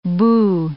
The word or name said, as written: boo